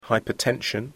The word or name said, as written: hypertension